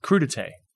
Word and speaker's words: crudité: I work in aviation, and there's a thing that's common in catering for private flights called crudité. And it's spelled crudite. – Okay. – C-R-U-D-I-T-E.